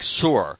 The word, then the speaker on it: Sur: as in S-I-R, and some people say it more like Sur, almost like a sewer, but not quite that pronounced, but like Sur.